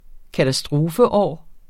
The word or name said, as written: katastrofeår